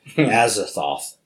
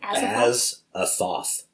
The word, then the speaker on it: Azathoth: Azathoth. This what? – Is his name. Azathoth.